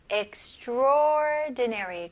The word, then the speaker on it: extraordinary: 하지만 발음할 때는 extraordinary. 그래서 A를 그냥 아예 무시를 하시는 게 좋아요. extra보다는 그냥 바로 O로 넘어가서 extraordinary.